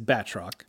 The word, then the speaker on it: Batroc: Well, first off, I hate to correct a pronunciation, but it is Batroc. – I'm sorry. – I think this might give you a clue as to who wanted him in the book. – It's pronounced Batroc, Jordan, but you might know it as Batcor.